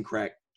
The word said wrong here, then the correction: Krech: did I pronounce his last name right? – Crack.